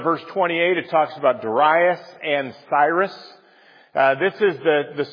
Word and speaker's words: Darius: This new ruler in Babylon, the kingdom of Babylon, Darius, which for all my life I said Darius, and I actually made the mistake of looking it up, and they say you should pronounce it Darius, so that's why I'm doing that.